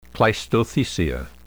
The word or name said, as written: cleistothecia